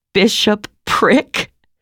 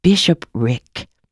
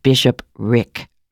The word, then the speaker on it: bishopric: "No, bishopric. You're not pronouncing it right." We had to hold on to each other to keep from falling. Neither of us could stop laughing. "It's the place he lives or something. Oh, God." She wiped the tears of laughter from her eyes. "Always be sure you pronounce it Bishop Rick."